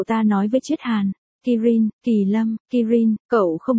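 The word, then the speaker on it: Kirin (キリン): Cậu trai dùng tiếng Trung bập bẹ và tiếng Nhật giải thích hồi lâu, có ý là hươu cao cổ trong tiếng Nhật phát âm là. Kirin, viết là, Kỳ Lân, mắt Kỳ Lâm mở to.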